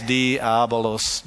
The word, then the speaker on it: Diabolos: His word, his name is Diablos. And you've seen it in music. You've seen it all around the place, Diablos. And phonetically, it's Diabolos, Diabolos. And Diabolos is only one of his names.